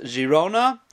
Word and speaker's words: Girona: In Hebrew it's spelled Gimel Yud Reish Vav Nun Hey, but the Gimel is really a J, so it's really pronounced Girona.